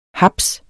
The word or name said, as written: haps